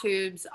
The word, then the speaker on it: Twoobs: So we're at Twoobs on Instagram. It's T-W-O-O-B-S. There's a silent W in there.